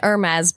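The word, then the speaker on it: Hermès: What's the it's an Hermes bag. – Oh, good thing we didn't miss that. – The H is silent.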